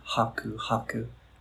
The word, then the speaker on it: haku haku: So in this language, the pronunciation often, not always, but often comes on the second to the last syllable. So it would be haku haku, which means it's a greeting we use in our community to mean hello.